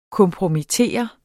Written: kompromittere

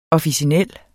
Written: officinel

